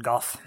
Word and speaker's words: Gough: Alfred Goh was the, at least I think that's how you pronounce his last name, G-O-U-G-H, just... – Alfred Goff. – Goff, yeah, I was gonna say that could be it too.